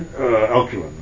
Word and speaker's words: Alcuin: The end of it, you list the Alcuin, am I pronouncing that correctly? – Alcuin.